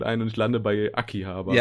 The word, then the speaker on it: Akihabara: Vor allem, ich sag eigentlich Anni Habara, aber das ist ja eigentlich auch von der japanischen Aussprache ist es falsch. Eigentlich ist deine Aussprache ja die richtige. Aki, Akihabara, äh, Aki.